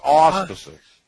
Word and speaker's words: auspices: As Justice Antonin Scalia said in the United States versus Williams, The grand jury should operate in the courthouse under the judicial how do you pronounce that? Gerard Aspreys? – Auspices. – Auspices. – Yeah, the auspices. – Auspices. Under judicial auspices.